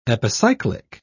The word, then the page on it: epicyclic: British English